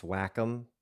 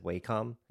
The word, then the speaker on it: Wacom: I mean, there's a whole line of, I think it's pronounced Wacom, but I always said Wacom.